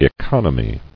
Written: Economies

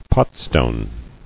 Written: Potstone

